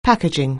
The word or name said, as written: packaging